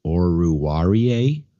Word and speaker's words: Oruwariye: But, you know, they're missing Oruwariye, I think is the official pronunciation, and Jeff Okuda, you know, to IR in that secondary.